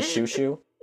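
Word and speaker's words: Xiu Xiu: do you know the band Shushu? – No. – Oh, it'll spelled like XIU.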